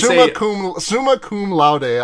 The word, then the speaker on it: summa cum laude: Is it pronounced load? – I think it's laude. – Oh, okay. Well, summa cum laude. – Yeah, it's summa cum laude, I believe, is the correct pronunciation.